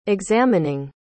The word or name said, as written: examining